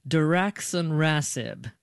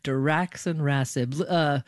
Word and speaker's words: Diraxonrasib: Diraxonrasib. Did I say that correctly, doctor? – Yes, well done. – Okay, Diraxonrasib.